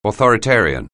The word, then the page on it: authoritarian: anglais